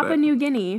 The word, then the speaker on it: Papua New Guinea: Papua New Guinea.